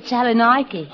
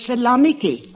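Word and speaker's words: Salonika: Mine's at Salonike. – Excuse me, but the correct pronunciation is Salonike.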